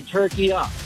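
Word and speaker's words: Türkiye: At the end is a short A. It's not a long A. In the Arabic language, it's called tortilla. So you could say turkey-a.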